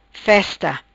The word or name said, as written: festa